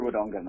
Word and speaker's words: Wodonga: Second one down in Albury, Wooronga. Am I saying that right? I'm not from that part of the world. – Albury Wodonga, mate.